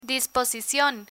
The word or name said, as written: disposicion